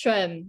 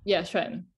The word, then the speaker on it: Xuan: It's Xuan, Xuan.